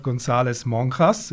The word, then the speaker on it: González-Monjas: Gewesen mit dem Dirigent und Giga Robert González Monjas.